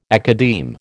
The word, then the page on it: academe: American English